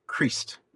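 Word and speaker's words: Crist: So, there's the discovery of the poem Creest by Cynewulf. – I think I'm saying that correctly. – I think you are.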